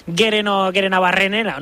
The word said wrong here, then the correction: Gerenabarrena: ¿no? – Bueno, pues, casi. Espera. – Gerenabarrena. – Ahí está.